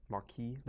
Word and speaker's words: Marquis: Marquis. – There's no you don't pronounce the S off.